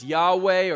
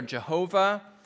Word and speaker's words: YHWH: It's actually used more than 6,000 times it's a, it's a series of four consonants that, that we typically uh, transcribe as YHWH. Sometimes you might hear it, uh, hear it pronounced as Yahweh or Jehovah.